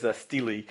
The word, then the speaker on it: stele: There's a word here called S-T-E-L-E. I didn't even know how to pronounce it, which is pronounced, it's a slab of stone with inscriptions on it, and the correct pronunciation, which he had to correct me, was stele.